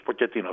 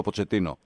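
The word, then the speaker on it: Pochettino: Μου δείχνει ότι ο Ποτσετίνο πάει να φτιάξει για άλλους Ποκετίνο. Δώσ' μου, εγώ Ποτσετίνο, οκ. Εσύ είσαι καλύτερος στις προφορές των Αργεντίνων. – Αυτός μας έχει ταλαιπωρήσει. Νομίζω έχουμε καταλήξει στο Ποτσετίνο. – Ναι, λόγω Ισπανικών, οπότε ναι.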